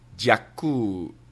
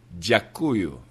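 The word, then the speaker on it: Dziakuyu (дякую): It's Dziakuyu. I hope my pronunciation was okay. Dziakuyu.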